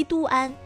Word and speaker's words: 都安: 如果说你想找一处夏天人少警觉小众不撞款，那就一定要锁死这个地方，广西都安。都呢就是都城的都，安就是平安的安。